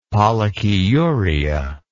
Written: pollakiuria

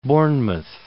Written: Bournemouth

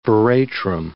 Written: veratrum

